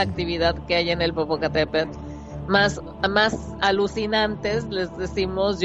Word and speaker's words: Popocatépetl: Popopacate, Venga, lo he dicho una vez. Live, hay livecamp, Popocatépetl y hay Livecam igual porque a,